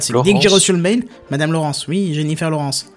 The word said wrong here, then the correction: Lawrence: Ça se prononce pas comme ça Oh, euh, c'est Lawrence.